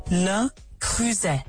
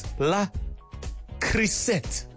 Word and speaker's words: Le Creuset: I'm going to play you the correct pronunciation. – Le Creuset. – Yes. Le Creuset. I don't know how many times I used to pronounce it as La Creuset.